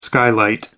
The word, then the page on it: skylight: American English